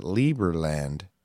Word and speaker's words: Liberland: I'm pretty sure they pronounce it Lieberland.